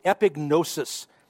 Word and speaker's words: epignosis: This is one that you may have heard someone pronounce it before. It's epignosis.